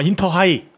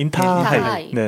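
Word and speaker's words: 인타하이 (インターハイ): 아, 인터하이. – 네네네. 네, 인타하이. – 네네네. 일본 발음은 인타하이.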